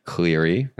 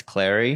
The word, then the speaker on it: Clary: You know what's funny is I had Laura Clary on my podcast, and I mispronounced her name. – What'd you say? – Cleary. – And it's Clary? – It's Clary. That's a tough one, though, because I just always pronounced her name wrong.